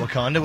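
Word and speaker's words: Wauconda: W-A-U-C-O-N-D-A. I'd pronounce that Wakanda, wouldn't you? – Probably would.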